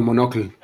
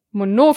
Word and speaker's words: Monokel: Oh. Monokel. Ja. Ist auf dem O.